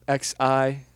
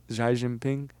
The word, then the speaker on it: Xi Jinping: X-I? – Xi. – And then is it Jinping? – Xi Jinping.